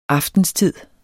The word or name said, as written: aftenstid